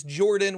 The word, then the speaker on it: Jorphdan: My name is Jordan with a silent PH in the middle, and I am joined always by my wonderful co-host, Sir Lucian, over at Sir Lucian Gaming.